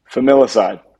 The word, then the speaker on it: Familicide: Man, I, I can't even pronounce it right um, is it family aside? Uh, Familicide. Familicide.